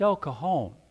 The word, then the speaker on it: El Cajon: And he said, No, it's, it's El Cajon. The J is silent.